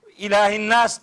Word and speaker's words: İlahin Nas: ilah yani esre okunuyor. İlahin Nas dendi.